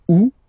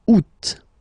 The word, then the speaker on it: août: And then here, two options, because, well, two options are acceptable in French. The first one, you don't pronounce the final T. Ou. Ou. Ou. Second one, you pronounce it. Août.